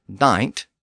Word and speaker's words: knight: So the modern spelling of knight, K-N-I-G-H-T, reflects the fact that the K was once pronounced.